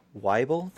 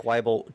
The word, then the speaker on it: Waibel: If you search for Jack Waibel, that's how you say your last name, right? – Yep. – Jack Waibel, Waibel, on the DMs Guild, you can find all the adventures that Jack has written.